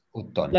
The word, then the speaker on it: automne: This one is sounds like this. Automne. – L'automne. – Automne. – L'automne. – Automne. – L'automne. – Automne. So actually, you do pronounce the N at the end.